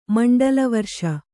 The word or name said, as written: ಮಂಡಲವರ್ಷ